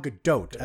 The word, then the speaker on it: Gadot: Gal Gadot, I think is how you say it. – Oh, is it a hard T? – You do pronounce the T.